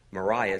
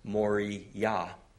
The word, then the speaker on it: Moriah: You have Mount, actually it's not pronounced Moriah, it's pronounced Moriah.